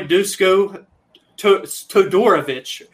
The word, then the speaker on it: Dusko Todorovic: Our boy Dusko Todorovic is how they say his name. We finally hmm. learned how to say it properly.